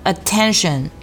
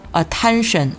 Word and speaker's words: attention: 再来看第四个重点单词叫做 attention，这个单词呢我们注意字母 e 发的是一个小口的的音啊，不要读成 attention 啊。我们在发这个 at 的音的时候，开口切记不要太大啊，开口只有一指宽，然后非常的干脆利落短促，把这个音发出来 attention。